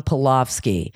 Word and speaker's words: Pelofsky: You can find me at the convenient name of at Sean Pelofsky. – Better spell that last name. – Yeah, P-E-L-O-F-S-K-Y, and it's S-H-A-W-N.